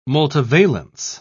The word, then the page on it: multivalence: British English